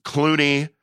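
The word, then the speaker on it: Cloonee: There's Clooney.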